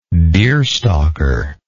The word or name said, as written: deerstalking